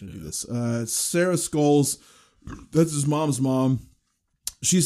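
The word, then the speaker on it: Schols: Oh, Scholes is how it's pronounced. Sorry. Okay. It's S-C-H-O-L-S, but when I...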